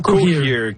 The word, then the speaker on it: Cohere: Comment ça se prononce, Cohere? – Oui, c'est bien ça, Cohere.